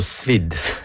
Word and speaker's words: sous vide: Ja, men uttales det også sous vide, driver Kjell. – Ja, morsomt.